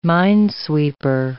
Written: minesweeper

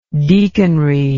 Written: deaconry

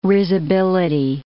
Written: risibility

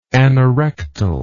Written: anorectal

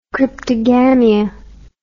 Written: cryptogamia

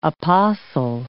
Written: apostle

